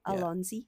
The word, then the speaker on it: Allons-y: Allons-y.